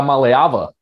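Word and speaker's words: Iamaleava: That obviously starts with Arch Manning, Malachi Nelson, who's committed to USC, Nico Iamaleva, who's committed to Tennessee. Did I say that right? – Yeah, Maleava. You're very, very close.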